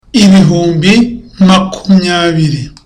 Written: ibihumbi makumyabiri

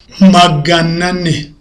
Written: magana ane

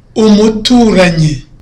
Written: umuturanyi

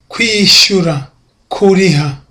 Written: kwishyura

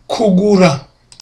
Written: kugura

